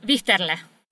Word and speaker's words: Wichterle: Wichterle, is that how I pronounce his name? Otto Wichterle. – Otto Wichterle. Wichterle.